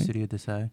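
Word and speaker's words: Studio d'Essai: and his pupils in the foundation of the Studio Desai. Is that how you pronounce it? – Yeah, Okay. – Studio Desai.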